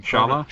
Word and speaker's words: Schala: Ooh, such as being able to revive Aerith in Final Fantasy VII or that there is a way to find, uh, God, how do you pronounce her name? Scala in Schala, Schala in yeah.